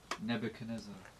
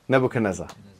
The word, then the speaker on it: Nebuchadnezzar: Nebuchadnezzar. – Nebuchadnezzar.